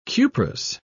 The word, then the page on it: cuprous: British English